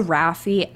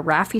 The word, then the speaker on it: Rafiee: Rafi spelled R-A-F-I-E-E, spelled a little funny. It's Persian, but it's probably pronounced and spelled wrong, to be honest. But I've had people tell me that. They're like, your last name is not only spelled wrong, but it's also pronounced wrong.